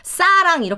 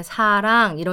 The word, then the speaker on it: 사랑: 쓰면 싸랑 이렇게 읽어요. – 음. 그러니까 그게 걔네는 S 발음을 세게 하거든요. 그래서 그런 것도 이제 예를 들면 입술을 거의 뭐 만지지 말고 부드럽게 발음을 해라. 이렇게 사랑